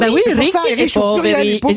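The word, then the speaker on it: Ricchi e Poveri: Julie me dit qu'il faut dire Ricky et Poveri. – Ouais, exactement. – Enfin, je suis désolé. On entendait les animateurs tout le temps dire Richie et Poveri.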